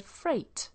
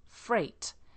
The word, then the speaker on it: freight: It's got the GH in it that's silent. It's spelt F-R-E-I-G-H-T. A bit like fright, but we have an E in it, freight.